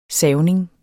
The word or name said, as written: savning